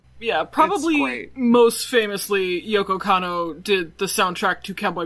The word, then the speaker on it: Kanno: Yeah, Yoko Kanno. – Yoko Kanno, yeah.